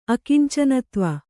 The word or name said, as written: ಅಕಿಂಚನತ್ವ